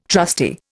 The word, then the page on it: trusty: Indian